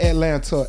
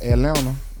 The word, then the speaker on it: Atlanta: Not Atlanta, Atlanta. – Atlanta. – You know what I'm saying? The T is silent.